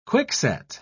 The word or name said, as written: quickset